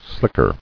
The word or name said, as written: Slicker